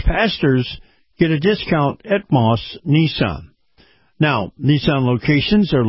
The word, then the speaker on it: Maus: Now, Moss is spelled M-A-U-S.